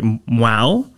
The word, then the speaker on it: Hmong: And it's spelled H-M-O-N-G, but pronounced, hopefully I'm doing this right, Mwau. – H-M-O-N-G.